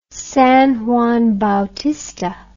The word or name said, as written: San Juan Bautista